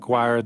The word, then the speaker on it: Quoir: It's spelled Q-U-O-I-R, but it sounds like choir.